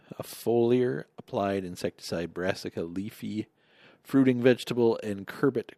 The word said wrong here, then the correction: cucurbit: crops, or curcubit, cucurbit, I think it is pronounced, and Zavalgo, a foliar-applied insecticide for potato and tree fruit pest management.